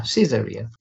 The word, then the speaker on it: Caesarea: Yeah, Caesarea.